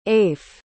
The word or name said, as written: aife